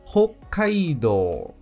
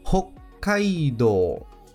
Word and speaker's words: Hokkaido: Com um Chi Sai Tsu ou seja, haverá aquela pequena pausa antes de pronunciar a sílaba seguinte. E compõe o nome da província Hokkaido. Hokkaido.